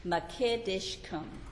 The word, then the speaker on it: Mekaddishkem: It is called Jehovah Mekedeshkum. M-E-K-A-D-D-I-S-H. K-E-M, Mekedeshkum.